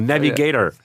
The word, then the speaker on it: Navigator: Und zwar englisch ausgesprochen, Navigator.